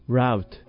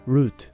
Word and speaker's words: route: ナレーターさんは route と発音していましたが、 route、 route、どちらもあります。